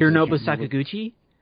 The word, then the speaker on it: Hironobu Sakaguchi: Hironobu Sakaguchi? – Yes.